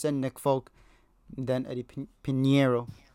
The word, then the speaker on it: Pinheiro: I found out how to say his name. Pinheiro.